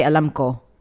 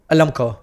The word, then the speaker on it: alam ko: You can't put like a W at the end. You have to say Alam Ko. – Alam Ko.